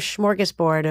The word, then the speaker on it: smorgasbord: A sort of smorgasbord of did I do say that right? Smorgasbord. – You did that beautifully.